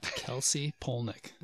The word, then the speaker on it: Kelsy Polnik: By my name, at Kelsey Polnick. – Now you got to spell that. – K-E-L-S-Y. P-O-L-N-I-K.